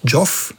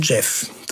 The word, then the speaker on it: Geoff: Und übrigens, seinen Vornamen schreibt man ja G-E-O-F-F, aber man spricht nicht Joff, wie viele Leute das tun, sondern Jeff.